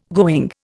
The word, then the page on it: going: Indian